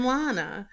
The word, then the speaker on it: Lana: I have never heard anyone pronounce it differently than Lana. In the last 11 years, I've been taught differently. Almost everyone initially pronounces it as though it rhymes with Hannah.